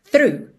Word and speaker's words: through: Aqui, Acho que não tem mistério nenhum, só essa palavra through, para quem está lendo, às vezes parece muita informação. T-H-R-O-U-G-H. Mas a pronúncia é simples: through, through. Ok?